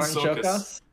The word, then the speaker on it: Csokas: Martin Shokas. – Martin Shokas.